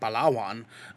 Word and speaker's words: Palawan: But, so right now she's in one of the smaller places called Palawan, which is a very, very, very, pronunciation on point.